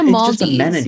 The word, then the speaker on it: Maldives: And it was in the Maldives. Did I say that right, Dan? – You said it right. Our ex-dean did not say it right.